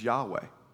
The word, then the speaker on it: Yahweh: So they took the vowels from Adonai and they put them into this and created a word that we pronounce as Yahweh.